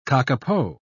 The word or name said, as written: kakapo